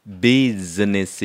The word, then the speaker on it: business: Business 이래야지 비치라고 하면 안 돼요. Busy는 바쁜 거잖아요. 근데 발음이 없어요. Business 해야지 한국 사람은 business 이랬는데 비지가 아니라 비즈 이렇게 해야 돼요.